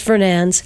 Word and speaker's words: Fernandez: Keep each other accountable with the help of Sherry Fernandez, pronounced Fernandes, Sheets.